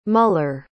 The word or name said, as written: mueller